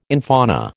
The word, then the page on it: infauna: American English